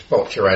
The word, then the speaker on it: Boccia: So it's Boccia as in gotcha, but it's spelled B-O-C-C-I-A, Boccia.